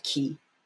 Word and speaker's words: Quay: Q-U-A-Y is pronounced Key.